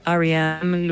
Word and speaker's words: R.E.M.: ¿Está bien pronunciado? – Sí, bastante bien. Sonó natural. Si quieres, para pulirlo, sería algo como R.E.M., Losing My Religion.